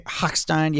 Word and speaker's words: Hochstein: So I'm sorry if I'm butchering this lady's name, but Lisa, Hot- Lisa Hochstein, is that how you say her name? – Hochstein, yeah, something like that.